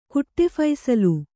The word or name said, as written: ಕುಟ್ಟೆಫೈಸಲು